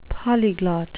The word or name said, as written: polyglot